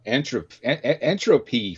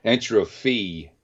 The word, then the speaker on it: Entropy: Mr. Danny Lauzon, he of entropy fame. Oh, I can't even pronounce that word. I always want to say entrophy. Okay, but it's Entropy, who were a really, really awesome thrash band from way back in the day.